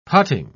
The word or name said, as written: putting